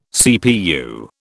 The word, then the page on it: CPU: British English